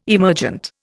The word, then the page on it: emergent: Indian